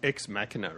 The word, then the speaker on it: Ex Machina: So it's Ex Machina, isn't it? Not Ex Machina. – Well, I believe it's Ex Machina, but I'm willing to be corrected on this.